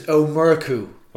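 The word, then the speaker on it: Ó Murchú: It's Omurku. – Omurku, okay. – So it looks like Omurchu.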